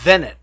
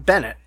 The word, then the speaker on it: Vennett: Vennett, not Bennett. Vennett. – Yeah, Vennett. Yes. Thank you for correcting. – Sorry. – Yes. Well, I'm ashamed that I said that incorrectly. – I have it pulled up right in front of me. But he did make mention that it was like double V's.